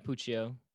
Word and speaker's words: Puccio: Is it Puccio? Is that how you pronounce yeah. – it? – Yeah, Puccio.